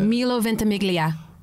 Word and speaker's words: Milo Ventimiglia: and Milo Ventimiglia. How do I say that? – Milo Ventimiglia.